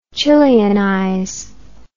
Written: Chileanize

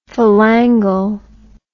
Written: phalangal

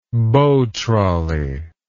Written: bow trolley

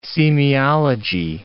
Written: semiology

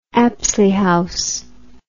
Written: Apsley House

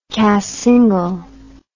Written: cassingle

